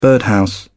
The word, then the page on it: birdhouse: British English